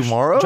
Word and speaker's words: Dumaro: Dumaro. – Josh Dumaro. It rhymes with tomorrow.